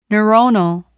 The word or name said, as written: neuronal